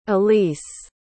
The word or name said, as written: alyse